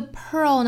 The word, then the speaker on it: pearl: 那这个 pearl 呢跟 girl 的发音是一样的，我们除去前面的辅音就是 earl 实际上是一个卷舌这个音搭配后面舌侧音的处理，所以这个时候大家一定要注意舌头是有一个非常卷的状态，去过渡到舌尖抵上齿龈这样的动作，所以是 earl, pearl, pearl.